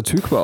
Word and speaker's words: Tykwer: Erstens wusste ich nicht, dass er Tykwa ausgesprochen wird. Mhm. Ähm, ich habe immer, mein, also mein Leben lang wirklich geglaubt, er heißt Tom Tykwer.